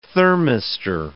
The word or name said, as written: thermistor